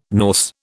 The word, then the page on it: Norse: British English